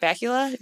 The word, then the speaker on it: Bakula: Archer, Scott, how do you say it, his last name? Bakula? Scott Bakula? Is it like Dracula? – Yeah.